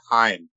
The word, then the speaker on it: Haim: They go by Haim. – Yeah. Haim. That's what I thought. – Haim. – Yeah. – They officially have said it rhymes with time.